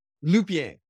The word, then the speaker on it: Lupien: How do you say your last name? – Lupien. – Lupien. Oh, just like it's spelled.